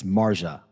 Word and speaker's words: Marjah: So first of all, ladies and gentlemen, that for those of you listening, I mispronounced. It's Marja.